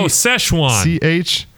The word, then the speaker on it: Szechuan: Szechuan. – Szechuan, okay. Szechuan pepper berries. And is infused with mango. – You gotta get some of the Szechuan pepper berries and for the dipping sauce.